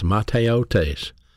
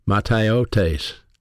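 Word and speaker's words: mataiotes: That word vanity is a Greek word in the Greek New Testament pronounced mateotes, mateotes, and it's a word for an empty space or a void.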